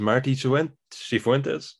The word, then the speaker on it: Martí Cifuentes: And someone else to keep an eye on, Lee, is Martí Cifuentes. Have I said that right? I think you're close enough.